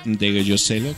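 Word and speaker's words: Ndegeocello: Nunca he sabido cómo se pronuncia bien su apellido. De Giocello, se escribe De Giocello. Michelle De Giocello, que así se pronuncia.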